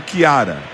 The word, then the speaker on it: Ciara: Na verdade, se pronuncia como Kiara.